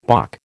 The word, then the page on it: bock: American English